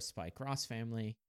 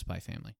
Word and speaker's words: Spy x Family: They usually pronounce it as cross. – Yeah. – So that's why it's like Spy X Family or Spy Cross Family. – Or just Spy Family.